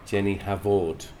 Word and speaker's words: Jennie Havord: Ladies and gentlemen, and boys and girls and everyone else of all the denominations, Jennie Havord. – Yes. – Did I say it right? – You did.